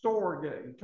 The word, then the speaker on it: storge: And then there's another one, pronounced storge. Turn your Bibles to Romans 1, verse 31, storge.